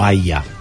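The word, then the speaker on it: Hayya: que se llama AYA, o AYA se pronuncia, que es lo que te permite ingresar al país y es como una visa temporaria mientras dure el Mundial.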